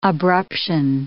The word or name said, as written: abruption